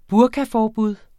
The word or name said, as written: burkaforbud